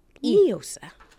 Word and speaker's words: NIOSA: And I pronounce it NIOSA. – And I pronounce it Neosa, because in Espanol, la palabra es pronounced Neosa.